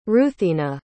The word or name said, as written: ruthina